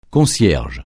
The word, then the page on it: concierge: francais